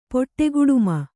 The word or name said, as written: ಪೊಟ್ಟೆಗುಡುಮ